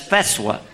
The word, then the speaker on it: fatwa: We have a fatwa. Said, I'm sorry, brother, you're mispronouncing that. That's feswa.